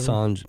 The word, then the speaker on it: Assange: So, Julian Assange, is that how you pronounce his name? WikiLeaks? – Assange or whatever. – Assange, yeah.